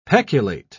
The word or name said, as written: peculate